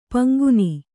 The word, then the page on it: ಪಂಗುನಿ: paŋguni